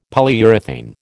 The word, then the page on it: Polyurethane: American English